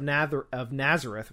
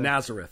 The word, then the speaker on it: Nazareth: So, again, so he's referred to as Jesus of Nazareth. Well, I'm going to just butcher that name the rest of the... – Nazareth.